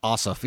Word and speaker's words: Ossoff: And let's take Ossoff. Is that how you say his name? Like the guy that lost in Georgia. – Ossoff.